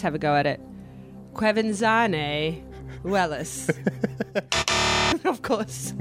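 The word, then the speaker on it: Quevenzhané Wallis: It is Quevenzhané Wellis.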